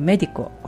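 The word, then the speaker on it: médico: で、例えば、お医者さんっていうメディコ、これメディコで 3 音節なんですけれども、一番最初の目のところを一番強く発音するので E の上にアクセント記号が必要です。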